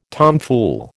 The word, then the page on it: tomfool: American English